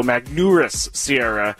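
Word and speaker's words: Magneuris Sierra: That was a brilliant way of pronouncing that, Jesse. I knew it was, I heard him say it earlier in the game, and I couldn't find the pronunciation, so Magnuris Sierra with that one.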